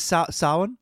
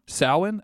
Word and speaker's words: Samhain: This is Samhain? – Samhain? Okay. – Otherwise known to Americans as Sam Hain.